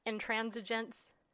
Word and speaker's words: intransigence: Intransigence. Did I say it right? – Yes.